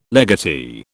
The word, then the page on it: legatee: British English